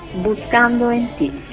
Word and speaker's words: Buscando en ti: Buscando en ti, è giusta la pronuncia? – Buscando en ti, sì.